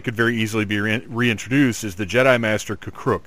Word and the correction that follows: K'Kruhk: If you don't know that name, it's because I pronounced it wrong, probably. – Okay. – It is K'Kruhk.